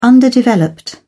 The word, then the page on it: underdeveloped: British English